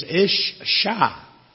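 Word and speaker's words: ish-sha (אִשָּׁה): And we would spell it I-S-H. The word for women is ish-sha, reflecting the fact that woman was derived from the man.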